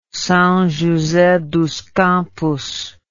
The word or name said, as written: Sao Jose dos Campos